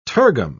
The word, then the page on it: tergum: British English